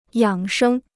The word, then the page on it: 养生: yǎng shēng